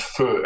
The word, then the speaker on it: pho: It's spelled pho, but it's pronounced pho, and it's just beautiful.